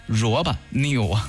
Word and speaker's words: RoboNeo: 因为它是英文 ROBONEO，萝卜 new。